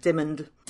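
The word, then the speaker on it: Dymond: It's Diamond, but spelled with a Y. – Yes. I think they pronounce it Dimond.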